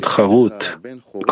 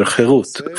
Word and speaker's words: chirut: And in the Mishnah, charut, carved on the stones, do not pronounce it charut, carved, but rather chirut, freedom.